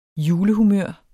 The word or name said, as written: julehumør